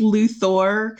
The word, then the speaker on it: Luthor: I think it's Luthor because it's an O-R.